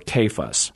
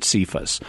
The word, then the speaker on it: Cephas: And it becomes Cephas or Kephas. – Okay. – In English, we then pronounce it Cephas.